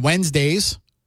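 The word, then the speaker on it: Whenzdaze: A band that blew me away was a pop punk group called Wednesdays, not spelled like Wednesdays, but it's spelled like W-H-E-N, Wen, then a Z, then a D-A-Z-E.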